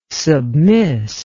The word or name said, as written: submiss